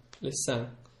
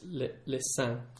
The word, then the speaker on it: sein: And the word for breasts, or I think it's the same word for nipples, is the word sein, le sein, and that's spelled S-E-I-N-S. It's not the same as the Parisian river La Seine. – Yeah. – It's slightly different. It's le sein. – Le sein. – S-E-I-N-S.